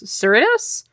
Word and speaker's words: Cerritos: The more adult-oriented cartoon returns for a second season of side-splitting hijinks aboard the USS Cerritos. Cerritos? – You actually said it right.